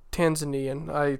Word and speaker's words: Tanzanian: Is it Tanzanian or Tanzanian? – Tanzanian. I snuck an extra syllable in there.